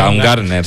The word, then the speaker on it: Baumgartner: Baumander, o como se pronuncia. – Baumgartner, sí.